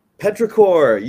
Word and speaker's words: Petrichor: Now, correct me if I mispronounce it, but I think the name of the track is called Petrichor. – Petrichor.